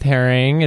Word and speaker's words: Haring: You're wearing a wonderful Keith Harrington shirt. – Yes, love Keith Haring. It felt... – Haring? I changed it to Harrington. – Yeah, you know, just add some syllables.